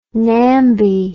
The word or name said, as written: namby